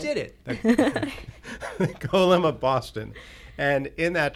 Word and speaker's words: Golem: I'm saying that correctly. Golem. – Not Gollum from Lord of the Rings.